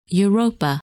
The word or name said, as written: Europa